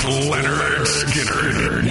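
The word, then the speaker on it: Lynyrd Skynyrd: Pronounced Leonard Skinner with Hairball tonight.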